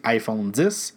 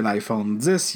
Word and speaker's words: iPhone X: Donc, euh, je vais passer rapidement sur l'iPhone X, mais qu'il faut prononcer iPhone 10.